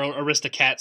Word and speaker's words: Aristocats: maybe The Aristocrats a little bit, or Aristocats, excuse me, I mispronounced that.